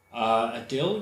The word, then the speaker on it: Adil: So here is Adil and David Adil, or Adil, or how do you say it? – Adil, usually, in some places and contexts.